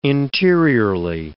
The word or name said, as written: interiorly